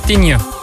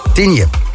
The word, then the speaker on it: Tignes: Dan Tignes, Tignes, hoe zeg je dat? Spreek je dat uit? – Tignes. – Tignes. Ja.